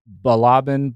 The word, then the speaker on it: Balaban: Mario Balaban.